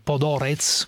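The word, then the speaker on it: Podhoretz: Che il leader dei cosiddetti neocon americani, che si chiama Norman Podoretz, così è il, si pronuncia? – Sì, esattamente così.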